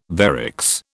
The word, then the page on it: varix: British English